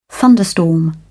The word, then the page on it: thunderstorm: British English